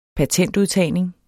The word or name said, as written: patentudtagning